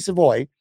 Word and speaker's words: Savoie: But pick nine was one of the guys that I actually did spend a good amount of time reading because Matthew Savoy. And I did say that right, correct? – Yes, you did. You nailed it.